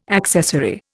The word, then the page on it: accessory: Indian